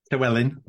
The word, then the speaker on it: Llewellyn: Llewellyn.